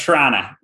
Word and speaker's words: Toronto: You know when someone's from Toronto and they don't pronounce the second T? It's like Toronto.